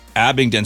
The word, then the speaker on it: Abingdon: And then Maddie in Abingdon, Maryland. Whoa, nice. Okay. First time giver. Hey, thank you so much. I hope I said that right. Abingdon, Maryland. – Abingdon sounds Abingdon.